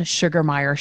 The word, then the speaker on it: Sugermeyer: As long as you spell the name right, I am the one and only Jen Sugermeyer. Sugar is spelt with an E. It's more like Hooked on Phonics.